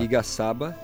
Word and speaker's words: Igaçaba: É, a primeira é Igaçaba, como é, como é que pronuncia aqui a, Tem uma música no disco chamada é, Igaçaba. Igaçaba. Isso.